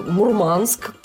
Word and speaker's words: Мурманск: Вот скажите, пожалуйста, почему моряки ставят ударение не там, где привычнее всем остальным людям? Шторма, они говорят, ветра, говорят. Ну, что еще? Мурманск, классика жанра.